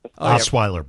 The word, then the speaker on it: Osweiler: So I'll take, I don't even know how to say his name, Osweiler. – Osweiler, Brock Osweiler.